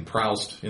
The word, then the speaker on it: Proust: Actually, my friend Camille gave me grief for saying Proust in the first podcast. He goes, it's not pronounced Proust, it's pronounced Proust.